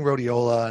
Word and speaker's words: rhodiola: robus, uh, rhodiola rhodiola rhodiola yeah.